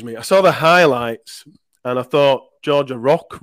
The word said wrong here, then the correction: Roach: Roach, just... – Roach, yeah. – Roach, yeah. I never know how to pronounce that, Roach.